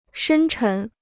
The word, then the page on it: 深沉: shēn chén